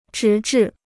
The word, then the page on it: 直至: zhí zhì